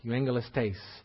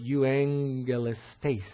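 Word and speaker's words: Euangelistes (εὐαγγελιστής): Euangelistes. And the double G, it's spelled Euagelistes, but the double G like that is pronounced as an NG. So it's Euangelistes. The emphasis is on the last syllable there.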